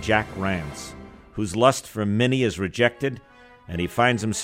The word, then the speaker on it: Minnie: It's the story of Minnie, Meanie in the Italian pronunciation, a tough but tender woman who is loved by the California gold miners to whom she is a devoted mother figure.